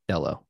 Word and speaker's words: Dello: I don't think CZ has the same character or metal that Arthur Hayes and crew, Sam Reed and Ben, how do you say his last name? – Dello? – Dello, yes, Ben Dello.